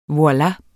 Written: voila

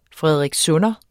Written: frederikssunder

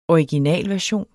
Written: originalversion

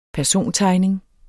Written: persontegning

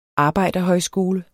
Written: arbejderhøjskole

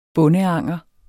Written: bondeanger

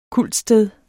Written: kultsted

